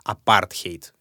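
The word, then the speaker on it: apartheid: Собственно, вот это слово апартеид, кстати, правильно его произносить, апартхейт, это если с языка африканс, но как-то так уже сложилось.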